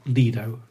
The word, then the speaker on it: Lido: Anyway, extensive research done by your team here shows that the correct pronunciation is Lido, a small short L.